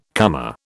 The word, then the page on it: comer: British English